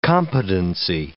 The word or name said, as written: competency